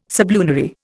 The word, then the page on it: sublunary: Indian